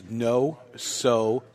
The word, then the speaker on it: Nosotros: Rudy put it up phonetically. It's Nosotros.